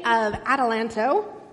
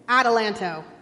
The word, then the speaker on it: Adelanto: And it is found outside of Victorville in the city of Adelanto. I know I say that wrong every time. Is that right? Adelanto. I always want to say Adelanto.